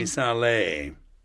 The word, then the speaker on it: Missale: Is it missalis in Latin? I'm just guessing. – Missale. In Italian, yeah.